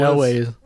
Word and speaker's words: Elwes: How do you say Elwes? – Elwes. – Elwes. – I always call it Elwes.